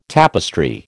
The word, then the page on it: tapestry: American English